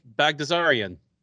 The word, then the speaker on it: Baghdasaryan: Give me the pronunciation again. See, because you know you're the pronunciation guy. – Melsik Bagdasarian. – Bakhtazarian.